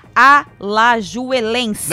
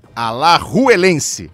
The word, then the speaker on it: Alajuelense: Alajuelense. – Não. Alajuelense. – Ah, obrigada, Everaldo Marques.